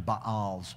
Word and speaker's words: Baals: And the people of Israel did what was evil in the sight of the Lord, and they served the Baals, or the Baals is technically how it's pronounced.